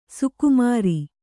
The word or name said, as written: ಸೊಕ್ಕುಮುರಿ